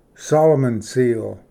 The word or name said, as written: ᎠᏖᏗᏍᎩ